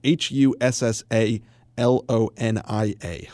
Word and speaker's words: Hussalonia: It's spelled H U S S A L O N I A, Hussalonia.